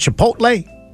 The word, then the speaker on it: Chipotle: Well, McDonald's and Chipotle, I say that right? – Mm-hmm.